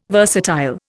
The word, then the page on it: versatile: Indian